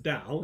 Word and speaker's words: Tao: We pronounce it Tao, it's T-A-O.